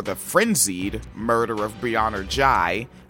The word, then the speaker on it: Ghey: I'm saying Ghey now instead of Ghey because G-H-E-Y, that looks like Ghey or Ghey, like silent H, right? But I found some material published to the YouTube with some folks from the UK talking. And they said her name and said Ghey, Ghey, Ghey.